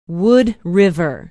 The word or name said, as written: wood river